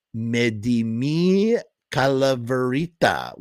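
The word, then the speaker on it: dame mi calaverita: Mexican children asking for candy by saying, me de mi Calaverita. Sorry, there's my gringo phonetic pronunciation of Spanish. Sorry. Medimi Calaverita, which means, can you give me my little skull?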